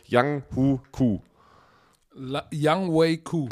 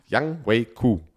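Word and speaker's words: Younghoe Koo: Young Wei Koo. – Ach. So wird ausgesprochen. Ich vergesse jede Woche. Mhm. Young Wei Koo.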